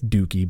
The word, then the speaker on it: Dookie: Did you just pronounce it Dookie? – Dookie, I said. It's Dookie, but yeah, I guess. But the same thing happened. – I've never heard someone pronounce it that way before.